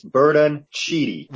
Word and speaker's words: Bertoncini: God, you mispronounce that every fucking, it's Bertoncini. – Well, you're saying it wrong.